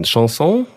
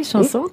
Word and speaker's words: Chanson: Du liebst das französische, genau, aussprechen, Chanson. – Oui. – Chanson, très bien. – Chanson, ja.